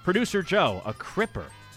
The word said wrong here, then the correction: crimper: A cripper or a crimper? – Oh, probably crimper. I'm probably saying it wrong.